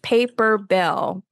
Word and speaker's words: Paperbell: Are you saying paper bill or paper bail? And if you're saying it, can you spell it? – Yes, paper bill. P-A-P-E-R-B-E-L-L. Paper bill.